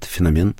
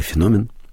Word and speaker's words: феномен: И вообще, знаете, когда мы подходим так, вот молодежь, надо этот феномен... или феномен, в зависимости от смысла, который вкладывается, ударение ставится.